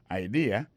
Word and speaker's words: idea: T, apostrophe S, good, G-O-O-D pour good, ça veut dire bon, bonne, idea, c'est I-D-E-A, ça veut dire idée, that's a good idea. Alors, accent vient tomber dans la deuxième syllabe là, one idea. Alors, moi, c'est idée, vous faites fait trois syllabes vraiment, I-D-A.